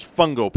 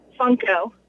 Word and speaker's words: Funko: The only thing on that kid's mind is Fungo Pops. That's it. Am I saying it right, Fungo or Funko? – Funko.